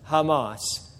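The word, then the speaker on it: Hamas (חָמָס): As we pointed out before, the word violence and the word violent is the Hebrew word, believe it or not, is the Hebrew word Hamas. That's how you say, that's the Hebrew word that's translated violence or violent is pronounced Hamas.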